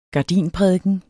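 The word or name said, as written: GARDINPRÆKEN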